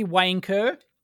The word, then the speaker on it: Wayne Kerr: I have to pronounce that correctly, Wayne Kerr.